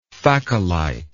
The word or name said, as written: phacolite